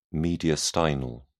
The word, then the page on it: mediastinal: British English